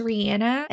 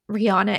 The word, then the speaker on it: Rihanna: And side note, her name is officially it's pronounced Rihanna, and that's the way she would pronounce it the entire world usually refers to her as Rihanna. So mm-hmm. this or that, whichever you prefer. I think that's the world has accepted Rihanna as her official name, even though it's not.